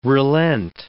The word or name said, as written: relent